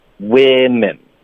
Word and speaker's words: women: 然后复数的，它的发音是 we 的音，women。